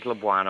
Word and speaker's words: Labuan: Now, I understand we're going to talk about Labuan Island. Have I pronounced that correctly? – You've done beautifully well there. It is Labuan Island.